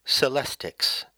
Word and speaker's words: celestics: So that term, of course, is celestics, C-E-L-E-S-T-I-C-S. Now, I think some of you may note, happily, that it rhymes with telestics.